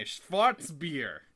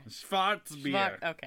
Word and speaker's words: Schwarzbier: I am drinking a Schwarzbier. You have to say it like that. – Schwarzbier.